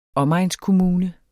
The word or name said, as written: omegnskommune